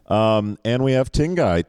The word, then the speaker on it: Tengai: It's pronounced Tengai. – Tengai. – Not Tengai, which we will probably mess up in the interview. But Tengai is officially how you pronounce it.